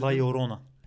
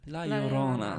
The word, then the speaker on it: La Llorona: Ayrıca Guatemala'nın adayı da yanlış da telaffuz etmek istemiyorum. La Llorona. – İzledim. La Llorona. La Llorona. Öyleymiş yani. Öğrendim. Ben de öğrendim. La Llorona.